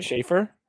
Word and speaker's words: Shafir: So Marina Shafir, am I saying that right? – Schaefer.